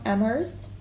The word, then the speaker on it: Amherst: Emily Dickinson, American poet from Amherst.